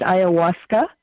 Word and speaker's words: ayahuasca: Is how you pronounce it, ayahuasca.